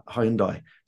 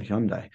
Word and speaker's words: Hyundai: Hyundai. Sorry, I'm pronouncing wrong, Hyundai. Okay.